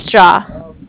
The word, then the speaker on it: Jia: My name is Jia, J-I-A, and I'm with Bank of Cardiff.